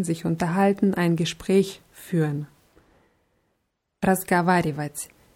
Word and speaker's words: разговаривать: Ja, das ist ein langes Wort, deswegen sollte man darauf achten, auch alle Silben auszusprechen. Ich sage es nochmal langsam, also reden, sprechen heißt Vat. разговаривать.